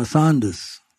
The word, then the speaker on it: Saunders: So that's on my mother's side, the Duncombe, D-U-N-C-O-M-B-E. But my father's side is on my Saunders, S-A-U-N-D-E-R-S.